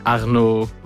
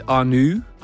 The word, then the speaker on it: Arnaud: My friends not being able to pronounce my name properly, so like Arnaud was difficult. They always said Arnu, and then from that somehow one evening a mate of mine started saying Nunzi.